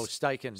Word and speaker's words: Steichen: Eagles offensive coordinator Shane, I don't know how you say it. Oh, Steichen. – Steichen.